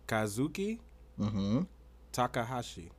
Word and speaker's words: Kazuki Takahashi: The name is pronounced precisely as Kazuki Takahashi.